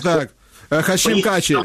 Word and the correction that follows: Тачі: Так, так. Тачі, Хашим Тачі, так.